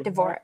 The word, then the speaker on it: Dvorak: Dvorak, Devo, how does... I pronounce the DV. I always feel like just it's Devo. – Christian Dvorak.